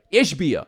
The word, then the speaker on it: Ishbia: Somebody in my mentions said about, was it Ishbia? Is that how you say his name? – Ishbia, okay.